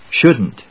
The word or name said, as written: shouldn't